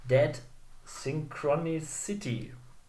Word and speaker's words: Dead Synchronicity: Das nächste Spiel heißt Dead Synchronicity.